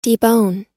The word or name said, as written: debone